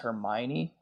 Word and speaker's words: Hermione: His friend that's a girl is Hermione, I think.